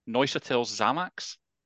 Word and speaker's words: Neuchâtel Xamax: Neuchâtel Xamax.